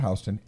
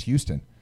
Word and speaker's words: Houston: I'm like, it's not Houston. It's Houston. – Yeah. – Because it's named after fucking Sam Houston.